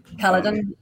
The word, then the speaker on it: Kaladin: I think actually when I picked up Way of Kings, I think I was just, is it Kaladin? Is that how you say his name? – Kaladin.